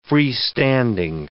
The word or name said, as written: freestanding